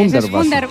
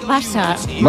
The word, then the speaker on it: Hundertwasser: la Casa Hundertwasser, ¿verdad? No sé si lo he dicho bien, que sí, el alemán bueno, es lo mío. – Me lo han corregido antes, Hundertwasser. Es Hundertwasser.